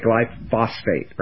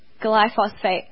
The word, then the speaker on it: glyphosate: They want to say glyphosate, right? – I want to say glyphosate.